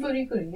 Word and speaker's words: Fooly Cooly: Fooly Cooly, yeah.